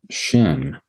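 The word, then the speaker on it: shin (שׁ): And if you have a left accented shin character, it's pronounced sin with no H. And if it's a right accented shin character, it's pronounced shin, which is the redemptive thing, which, for instance, we see in the name Shem, for instance.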